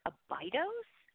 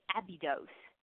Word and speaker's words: Abydos: So in the U.S., normally you hear Abydos, but in the U.K., Abydos is more common.